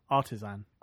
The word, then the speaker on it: artisan: I went up to Forge World with the guys from the Path of the Artesian. Artesian, is that how you say it? – Artisan. – Artisan? Right.